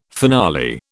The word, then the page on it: finale: British English